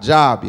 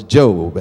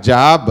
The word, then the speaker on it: Job: It's not Job, y'all. It's Job. Amen. It looks like Job, but it's pronounced Job. Amen. It sounds like it spells like J-O-B, but it's pronounced like J-O-B-E.